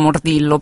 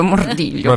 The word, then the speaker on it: Mordillo: Si legge Mordiglio in realtà, per cui è per quello che la gente sì. Sì, sì, in Italia diciamo Mordillo, però in realtà sarebbe Mordiglio.